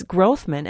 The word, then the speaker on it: Grothman: But no, it's Grothman, and I've heard people say Grothman.